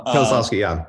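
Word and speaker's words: Kieślowski: The Krzysztof Kieslowski film. – Kieslowski, Yes, Blue.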